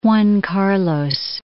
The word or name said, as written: Juan Carlos